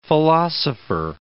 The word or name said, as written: philosopher